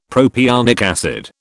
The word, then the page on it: propionic acid: British English